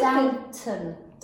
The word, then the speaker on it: Downton: What about that TV show, Downtown Abbey? – That's really Downton. – Downton.